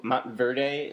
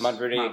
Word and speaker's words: Montverde: For people who don't know, Simmons came to Montverde. Is that how you pronounce it? – Yes. – Montverde Academy in Florida, the same school D'Angelo Russell went to, same school Florida's Casey Hill went to.